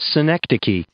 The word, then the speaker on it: synecdoche: Synecdoche.